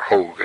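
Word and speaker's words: Hoge: Moses Drury Hodge, pastor in Richmond, played a direct role in Ewell's spiritual resurgence. He was a close friend of the family, or Hogue, I guess it's pronounced actually, Hogue took an active interest in Ewell's spiritual welfare, visiting frequently during his convalescence.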